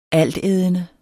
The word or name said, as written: altædende